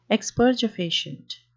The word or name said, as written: expergefacient